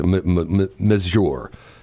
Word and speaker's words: majeure: And the idea behind force majeure, or I guess that's how it's majeure. The pronunciation is, it's just like force, F-O-R-C-E, that's how it's spelled, but it's M-A-J-E-U-R-E, M-A-J-E-U-R-E, and it is in most legal contracts.